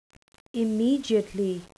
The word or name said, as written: immediately